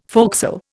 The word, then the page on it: forecastle: Indian